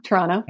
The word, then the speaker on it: Toronto: The second T in Toronto is silent, you know? – Well, it is to Torontonians. Yes. Toronto, yeah. – Toronto.